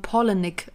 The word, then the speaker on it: Palahniuk: Im Englischen sagt man Polenick, als wären das zwei Namen Paula und ich hasse, Nick.